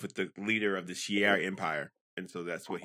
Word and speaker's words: Shi'ar: Shi'ar. – Shi'ar.